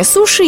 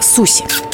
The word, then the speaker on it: суши: Кстати, лингвисты не согласны с вариантом произношения суши и предлагают называть это блюдо по всем правилам транслитерации с японского – суси.